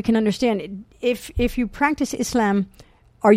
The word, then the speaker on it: Islam: Islam, yes.